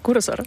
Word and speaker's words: Cursor: Do I pronounce it right? – Yes, it's called Cursor. – Cursor.